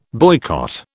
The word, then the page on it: boycott: British English